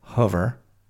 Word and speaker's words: hover: As I said, hover rhyming with lover.